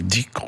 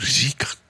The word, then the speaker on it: dhikr: Les pratiques soufis, qu'on appelle dhikr ou zikr, comme on prononce en persan.